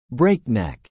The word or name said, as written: breakneck